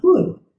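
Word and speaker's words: budh: Then she continues, awaken in Magadhi is pronounced Bud.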